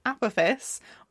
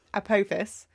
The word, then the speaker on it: Apophis: Well, okay, the reason why I'm struggling to pronounce it is because some people pronounce it with the stress on the first syllable and some people with the second syllable, and I'm a little bit undecided as to where I sit. So Apophis or Apophis. – Or Apophis. – Or Apophis. I'm going to go with Apophis.